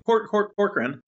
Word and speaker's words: Corcoran: Corcoran.